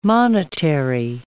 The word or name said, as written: monetary